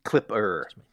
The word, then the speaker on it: Klipper: Is that Clippo, did you say? – Klipper. – Klipper. Klipper. yeah, k- with, and spelled with a K, I believe.